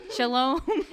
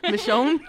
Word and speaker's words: Michonne: Shalom. – Michonne.